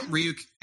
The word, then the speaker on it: Ryuk: I remember Ryuk. Ryuk? Am I pronouncing that correctly? – Yeah, Ryuk.